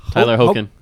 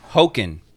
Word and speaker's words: Hoechlin: Hoechlin, as in Hoboken without the bow.